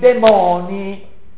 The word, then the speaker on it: demoni: Pico della Mirandola si schiera poi contro la magia che vuole evocare i demoni, con l'accento sulla O.